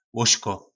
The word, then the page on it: lozko: po nasimu